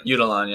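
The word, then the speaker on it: Yucatán: Their last ditch attempt, the Quiché would try to trap the Spanish inside the walls of Yucatan. Say that right. I'm Yucatan, yes. – Thank you.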